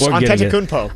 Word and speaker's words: Antetokounmpo: Giannis Antetokounmpo. Hopefully I can pronounce that properly. – That was close. Antetokounmpo. – Antetokounmpo.